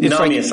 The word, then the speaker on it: Nahmias: It's Namias, like, I think. Yeah. – N-A-H-M-I-A-S. – Yeah.